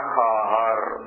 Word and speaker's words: خواهر: يقولون الواو إذا جاء بعدها ألف وكان قبلها خاء تلفظها ألف، مثلا خواهر، خواهر يعني أخت، ما يلفظون خواهر يلفظون خاهر، كأن الواو معدومة.